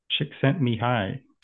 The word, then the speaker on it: Csikszentmihalyi: Csikszentmihalyi. – Csikszentmihalyi.